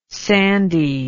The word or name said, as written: sandie